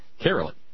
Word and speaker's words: Caroli: I'm supposed to call Caroli Caroli. Says it rhymes with merrily, or so she told... okay. Caroli. Merrily. Caroli. Okay.